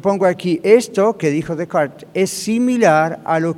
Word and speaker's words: Descartes: Ahora, un tal René Descartes, aquí se escribe Descartes, se pronuncia Descartes,